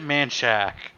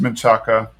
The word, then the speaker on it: Manchaca: Like, I think I said it Manchak, but what'd she say? Is it not Manchak? Is it Manchaca? It's Manchaca.